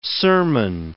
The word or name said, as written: sermon